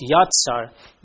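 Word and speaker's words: yatsar (יצר): It's also interesting to know that the Hebrew word for formed, yatsar, it's pronounced yatsar, means to mold into form, especially as a potter.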